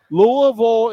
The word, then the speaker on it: Louisville: Game one today with Coastal Carolina and Louisville is the correct pronunciation. Louisville.